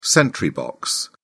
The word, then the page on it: sentry box: British English